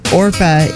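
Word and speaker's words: Orpah: So it's one of the few times a lifelong branding decision was made by people who couldn't pronounce the name correctly. – Now we know Orpa is her actual name.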